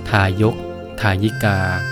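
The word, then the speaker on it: ทายก ทายิกา: สวัสดีครับพบกับรายการคำวัดวันนี้เสนอคำว่าทายกทายิกาค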